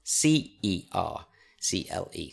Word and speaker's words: Cercle: But it turns out that Coco, his girlfriend, has this fantastic uh, business and it's called Circle. Um, and it's spelled C E R C L E, Circle. Can I tell you? Yeah. – Cercle. – Oh, so is it French?